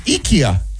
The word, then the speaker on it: IKEA: I've been saying it wrong? – Yeah. – This says it's IKEA.